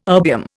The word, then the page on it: erbium: Indian